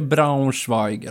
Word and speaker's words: Braunschweig: Och sen, ursäkta uttalet, är det Braunschweig eller? – Ja, bra uttal.